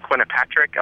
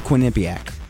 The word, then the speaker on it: Quinnipiac: I think it's called Quinnipiac. – Quinnipiac? – Quinnipiac. – No, that's not the way King pronounced it.